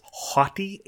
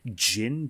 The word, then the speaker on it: Haughty Jinn: It is Haughty, H-A-U-G-H-T-Y, Jinn, D-J-I-N-N.